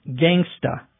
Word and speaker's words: gangsta: or as it's pronounced in the slang, gangsta.